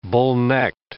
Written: bullnecked

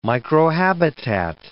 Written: microhabitat

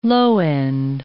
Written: low-end

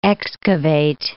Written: excavate

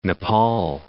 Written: Nepal

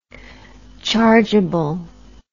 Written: chargable

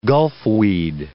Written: gulfweed

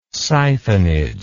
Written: siphonage

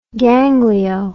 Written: ganglio

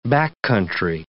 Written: backcountry

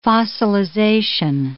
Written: fossilization

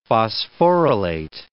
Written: phosphorylate

